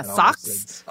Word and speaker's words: Socs: Because it's spelled S-O-C-S. – S-O-C-S. Yeah, socks. Socks and greasers.